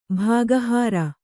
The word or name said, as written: ಭಾಗಹಾರ